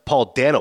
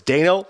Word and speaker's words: Dano: And Paul Dano, who's often, by the way, his name is pronounced Paul Dano. People always screwed up. I read this article. He said it's Dano like Drano. So people will think I'm screwing it up, but I'm saying it right.